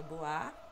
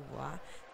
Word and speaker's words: Bois: Meu nome é Luciana Boá, obrigada a todos, e meu Twitter é o @LucianaBois, B-O-I-S, que se pronuncia Boá, sempre tem que falar isso, incrível,